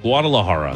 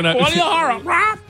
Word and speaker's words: Guadalajara: Guadalajara. – No. – That was close. – No, that might have been worse. Guadalajara. – Oh, okay. Guadalajara.